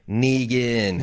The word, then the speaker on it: Negan: So I'm going to rock Negan until I hear Lincoln say Negan. – The official Watching Dead pronunciation of that name is Negan.